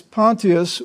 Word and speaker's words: Pontius: Sometimes you hear it pronounced Pontius Pilate. The difference depends on what side of the pond you're on. If you're in England, it's Pontius. If you're in America, it's usually Pontius. Since the Greek pronunciation is Pontius, we will go with that.